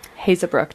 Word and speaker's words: Haezebrouck: And tell us a little bit about you, Dr. Hazebrook. Did I say that right? – Yes. That is correct.